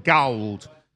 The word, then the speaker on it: Gawld: They're called Gawld. It's the whole pronunciation of gold. It's spelled G-A-W-L-D.